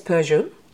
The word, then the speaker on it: Peugeot: Well, you know, we would say it's Peugeot.